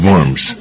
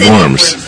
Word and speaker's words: Worms: Deed of worms? How do you pronounce that word? – Diet of worms. – What is diet? – Oh, worms. The W is pronouncing a V.